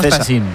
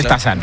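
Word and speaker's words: Stassin: Yo le llamo Stasin, pero se dice Stasan.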